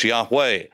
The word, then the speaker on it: Yahweh: We don't know how to pronounce his name. We pronounce it in the West, Yahweh.